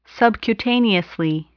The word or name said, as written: subcutaneously